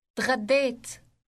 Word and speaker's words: تغديت: It contains a gargling sound similar to that of the Parisian R, if you are familiar with it. – تغديت تغديت Note that the last syllable contains a long vowel, and it sounds like the English word ate. Note also that the D sound in the middle is elongated.